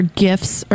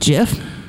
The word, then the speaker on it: GIF: And the guy who created it says GIF, and I'm just like, you're wrong. – It's not GIF. What's wrong? – Hard G for graphics.